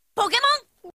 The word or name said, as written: Pokémon